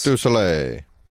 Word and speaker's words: Cirque du Soleil: Does he say it properly? – Probably not. – Am I? – Yes. – Cirque du Soleil. Soleil.